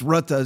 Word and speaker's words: Rutte: I mean, you had Mark Rutte, I think it's Rutte is actually the correct way to pronounce it. I asked a friend of mine from the Netherlands.